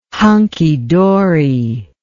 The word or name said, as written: hunky-dory